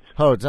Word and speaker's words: Hodes: Congressman Hodes, thanks so much for coming on with us. Hello from New York. – Good morning. How are you? I actually pronounce it Hodes. – Hodes, I'm sorry.